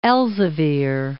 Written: Elzevier

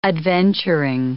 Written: adventuring

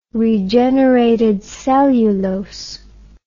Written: regenerated cellulose

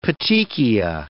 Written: petechia

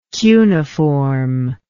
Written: cuniform

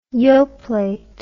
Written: yoplait

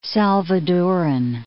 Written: Salvadoran

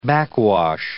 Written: backwash

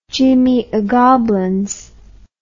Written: Jimmy O'Goblins

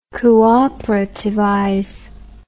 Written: cooperativize

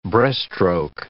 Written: breaststroke

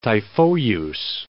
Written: Typhoeus

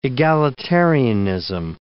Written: egalitarianism

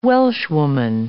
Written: Welshwoman

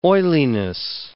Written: oiliness

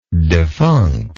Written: defunctness

